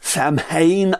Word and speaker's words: Samhain: Speziell jetzt Halloween wird ja in Verbindung gebracht mit dem Fest, was dann im irischen S-A-M-H-A-I-N geschrieben wird. Oft wird es Samhain ausgesprochen in amerikanischer Weise, aber in Irland sagt man tatsächlich Samhain.